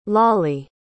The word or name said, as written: lollie